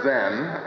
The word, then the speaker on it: Zen: The word Zen, as you undoubtedly realize, is a Japanese way of pronouncing a Chinese word, which is Zhan. And this Chinese word Zhan is a Chinese way of pronouncing a Sanskrit word, which is Jhana. And the Sanskrit word Jhana means contemplation. Contemplation of what?